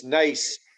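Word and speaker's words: Naas: Yes, Nace. Yeah, Nace. You spell it NAS, but it's you pronounce it Nace.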